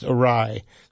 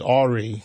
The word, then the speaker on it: awry: And I like that you knew it was pronounced awry. I've heard people mispronounce it as awry because they, never heard it pronounced.